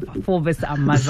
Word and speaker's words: Mazars: I've driven past that building so many times, and tonight is the first time that I'm learning that the S is silent at the end of Forvis Mazar.